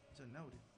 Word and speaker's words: Januário: Januário.